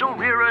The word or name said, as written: Ulrira